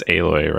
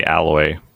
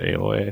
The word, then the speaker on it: Aloy: It's Aloy, right? – Yep, Aloy. – I keep wanting to say Alloy, but they pronounce it Aloy, I think, in the game.